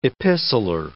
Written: epistler

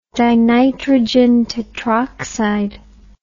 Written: dinitrogen tetroxide

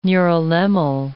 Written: neurilemmal

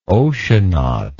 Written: oceanaut

